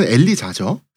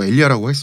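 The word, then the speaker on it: Elijah: 미국식으로는 엘리자죠. 스펠링이 네. 뭐 엘리아라고 했습니다.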